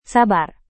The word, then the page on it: sabar: indonesian